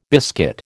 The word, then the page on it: biscuit: American English